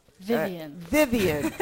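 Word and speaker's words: Vyvyan: Vivian.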